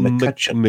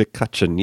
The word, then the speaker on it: McCutcheon: McCutcheon. McCutcheon, yes.